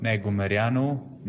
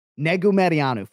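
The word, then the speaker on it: Negumereanu: So if you're showing up on fight day, Buff, and saying Negumarianu for the first time, you are not going to get it right. The last name is six fucking syllables.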